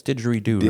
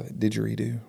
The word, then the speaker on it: didgeridoo: So please enjoy this this short clip of Jacob playing didgeridoo. Did I say that right? It's didgeridoo, right? – Yeah, didgeridoo.